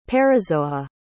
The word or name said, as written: parazoa